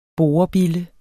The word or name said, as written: borebille